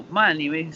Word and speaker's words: Mani: se escribe M-A-N-I latina. Eh, un amigo dijo como Mani, sí, como el mamut de la era de hielo.